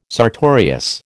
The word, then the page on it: sartorius: American English